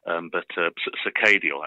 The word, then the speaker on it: Circadial: Circadial, actually, is the way that we pronounce it. But it's fine. It's a made-up word, you know. it's kind of a play on circadian, and then it's given a little bit of a Bristol twist at the end.